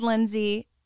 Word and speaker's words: Lindsey: Use code Lindsay, LINDSEY.